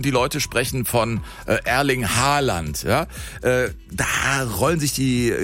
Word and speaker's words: Haaland: Er wird ausgesprochen Haaland, also mit O.